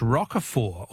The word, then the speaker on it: Roquefort: The French Roquefort, also with a silent T, with a silent T, is very smelly.